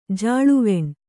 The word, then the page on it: ಜಾಳವಣೆ: kannada